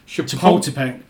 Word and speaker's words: Chapultepec: How do you pronounce this? – Chapultepec. Yeah. I suppose slightly different than that in the local lingo, but close enough.